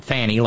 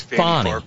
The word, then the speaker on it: Fani: And wrapping up, Matt says, Fani, and by the way, just a general reminder, that's how she pronounces her name. It is Swahili. She's not Fanny like in Fanny Flag. She's Fani. Fani.